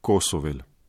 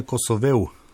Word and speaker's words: Kosovel: Zelo pogosto v medijih napačno izgovarjamo Kosovel. Srečko bi verjetno želel drugače. – Ja, pravilno je Kosovel.